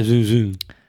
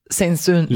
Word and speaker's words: Saint-Saëns: Si es francés, se pronuncia Saint-Saëns. – A ver, seguro entonces está así bien pronunciado. – Camille Saint-Saëns.